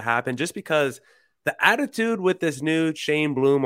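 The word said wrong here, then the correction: Chaim: Yeah, Heim Bloom.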